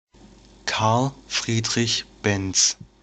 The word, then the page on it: Karl Friedrich Benz: German; [bɛnts]